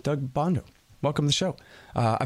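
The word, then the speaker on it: Bandow: All right, you guys, introducing Doug Bondo. Yeah, that's how you say it. Everybody knows that.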